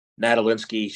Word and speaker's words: Nadolinski: Her last name, her first name's Elena, her last name's Nadolinski.